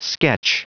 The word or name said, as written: sketch